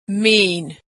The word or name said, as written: mean